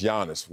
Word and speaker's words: Giannis: Say his name in homeland. Uh-huh, it's Giannis.